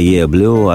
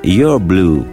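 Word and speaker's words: Yer Blues: «Е Блю» означает не больше, не меньше «твой блюз». Это типичное ливерпульское произношение не «your blue», а именно «е блю» — сленговое произношение.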